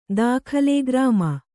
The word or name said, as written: ದಾಖಲೆ ಗ್ರಾಮ